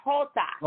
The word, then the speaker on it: jota: No, it's not. It has the H sound. J is the letter Jota.